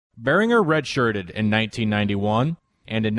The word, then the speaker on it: Berringer: And so I knew it was Berringer all the way.